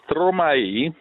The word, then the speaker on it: Stromae: Allora, si tratta di Stromae, che si scrive Stromae, ma a quanto mi dicono si pronuncia alla brussellese, con l'accento sulla ultima vocale che cambia da E a I.